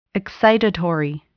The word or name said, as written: excitatory